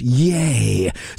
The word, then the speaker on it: YEA(H): We have an H in parentheses, so we're now pronounced yay.